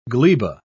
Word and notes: gleba: British English

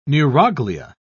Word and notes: neuroglia: British English

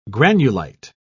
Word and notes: granulite: British English

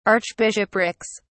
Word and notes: archbishoprics: British English

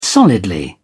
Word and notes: solidly: British English

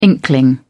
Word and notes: inkling: British English

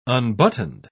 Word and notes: unbuttoned: British English

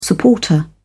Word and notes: supporter: British English

